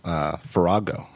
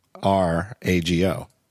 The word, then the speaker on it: Farrago: We're celebrating the release of a new Rogue Amoeba app, Farago. Am I pronouncing that correctly? – You are. – Farrago.